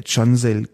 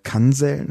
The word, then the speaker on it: Cansel: Es ist schon mal passiert, dass ich Namen falsch ausgesprochen habe, und zwar speziell auch türkeistämmigen Namen, zuletzt bei äh, Cansel Kisiltepe, die ich äh, Kansel nannte.